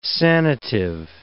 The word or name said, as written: sanative